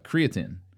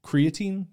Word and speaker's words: creatine: Wait, creatine? The thing that bodybuilders put in their shakes? – It's spelled the exact same way, but it's creatinine is how it's pronounced in the medical field. – Creatinine? – Creatin. – Creatine. – C-R-E-A-T-I-N-E.